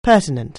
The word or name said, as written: pertinent